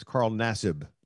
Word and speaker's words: Carl Nassib: You keep butchering his name. You can't butcher his name. – I butcher everybody's name. I call him Cos Nasby. Okay. Ain't that his name? – No, it's Carl Nassib.